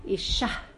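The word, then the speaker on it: Isha (אישה): If you're reading this like a very good Torah reader, we'll read it, Isha, like pronouncing the hey. It's called a ma'pik hey.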